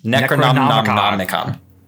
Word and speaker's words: Necronomicon: Necronomicon.